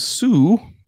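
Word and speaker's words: Hsu: A man named Sue, H-S-U, I think that's how you pronounce it,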